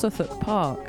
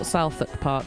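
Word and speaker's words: Southwark Park: So I've just been told that the park that I mentioned that Smurfs are playing at is actually pronounced Suthuck Park by my friend Mia. All right, so why is it spelt? Why is it spelt Southuck Park then?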